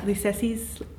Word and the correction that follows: Lucchese: Lucchese. – Lucchese, right. – Lucchese. – I'm saying it very wrong.